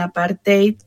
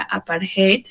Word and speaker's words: apartheid: lo que es el apartheid, porque, y aquí quería como mencionar esto, normalmente estamos acostumbrados a escuchar la palabra apartheid, sin embargo, apartheid es una palabra africana, por lo tanto lleva esa pronunciación.